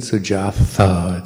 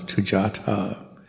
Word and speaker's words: Sujatha: But TH is not pronounced as we pronounce in English, the or they, it's pronounced T. So as an anthill or Sujatha. So his name isn't Sujatha, it's Tujatha.